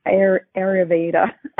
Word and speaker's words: Ayurveda: I don't know how to say it that well. Ayurveda. – That's it. Yeah.